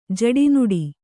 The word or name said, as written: ಜಡಿನುಡಿ